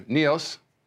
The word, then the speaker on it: Neos: Neos, sounds kind of like the Matrix, Neo. It's spelled like that, N-E-O-S, Neos.